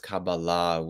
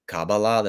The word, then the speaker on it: Kabbalah: I would define Kabbalah off the cuff, and by the way, I'll pronounce it as Kabbalah with the stress on the final syllable, but if anyone during the Q&A wants to say Kabbalah, that's totally fine.